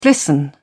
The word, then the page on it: glisten: British English